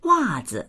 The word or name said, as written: wa4zi